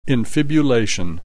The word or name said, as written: infibulation